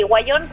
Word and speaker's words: Wayón: Perfecto, Radio Guayón se escribe W-A-Y-O-N, ¿verdad? – Sí, Guayón, que